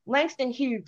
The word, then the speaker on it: Langston Hughes: Langston Hurst and uh, uh, Zora. Uh, did I did I, I said that wrong? Langston Hughes.